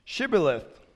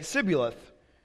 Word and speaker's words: Shibboleth: And they would ask, if the man said, no, I'm not, they would tell them, then say, Shibboleth. If he was from Ephraim, he would say, Sibboleth, because people from Ephraim could not pronounce the word correctly.